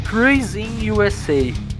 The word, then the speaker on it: Cruising USA: Como é que fala o nome desse jogo, cara? É Cruising USA.